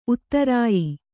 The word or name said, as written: ಉತ್ತರಾಯಿ